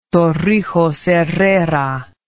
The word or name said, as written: torrijos herrera